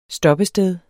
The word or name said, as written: stoppested